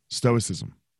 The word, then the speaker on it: stoicism: Stoicism.